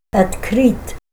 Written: atkrit

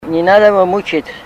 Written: ninadavamuchit

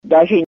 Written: dazhi

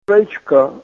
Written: rechka

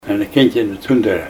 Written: Alfred's Flat

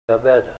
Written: abeda

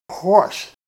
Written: hwos

